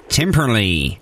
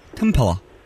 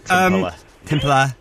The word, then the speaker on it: Timperley: Americans say Timperley. – That's right. – But here, Timperla. – Timperla.